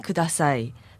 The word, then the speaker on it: Kudasai: Something, something, kudasai.